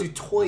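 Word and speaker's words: du Toit: I keep saying it wrong. – Dutoy. – That's the French way. – Yeah, it's Dutoy. – The South African way is Dutoy.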